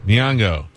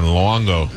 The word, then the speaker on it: Nyong'o: Is it Nyong'o? Is that how you say it? – Nyong'o. – Yeah. What did I say last time? Luongo.